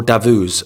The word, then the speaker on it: Davos: He represents, you know, the elite class in Davos, or Davos, as I was told it's actually pronounced.